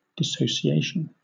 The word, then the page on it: dissociation: British English